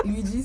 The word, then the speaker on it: Luidji: Donc Luigi, ça s'écrit L-U-I-D-J-I.